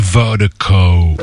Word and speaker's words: Virdiko: And Vertigo is spelled V-I-R-D-I-K-O. That's V-I-R-D-I-K-O. I can't speak. That's V-I-R-D-I-K-O. Pronounced Vertigo.